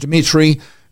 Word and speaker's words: Dimitri: You can also see it on my profile page, Dimitri, with three I's, D-I-M-I-T-R-I.